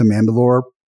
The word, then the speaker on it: Mandalore: I think they're going straight to Mandalore, probably.